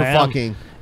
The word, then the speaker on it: Fucking: Dude, there's a sign for fucking. – It rhymes with booking, apparently. So apparently it's pronounced fucking.